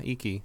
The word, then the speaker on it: Iki: But there's a new story chapter that is taking place on the island of Iki. Am I saying that right? – I think so. – Is that right? – IKI? – Yeah. – Iki. That should be right.